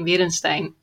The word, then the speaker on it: Werensteijn: Well, you pronounce it a bit German, and because it's Dutch, but it's really hard to pronounce, of course. But my name is Kim Werensteijn from Werk im Macho.